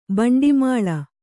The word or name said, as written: ಭಿಂಡಿಮಾಲ